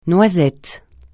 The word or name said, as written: noisette